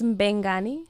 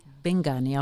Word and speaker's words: Mbengani: uh, lê-se Mbengani Bengani, ok. – Sim, é como se, o M é tipo. É mudo. Sim, é mudo, mas faz assim um efeito.